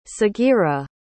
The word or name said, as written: sagirah